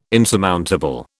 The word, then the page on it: insurmountable: British English